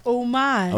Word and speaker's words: homage: Homage. – Homage. Then just why is there an H? – Because it's French. – It should be O-M-A-G-E. – O-M-G, Chris.